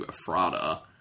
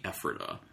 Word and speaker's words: Ephrata: There's a little behind-the-scenes joke going on where I mispronounced the name of the town initially to Ephrata. But apparently, if you're from Pennsylvania, you know that it's supposed to be Ephrata.